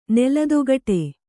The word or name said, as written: ನೆಲದೊಗಟೆ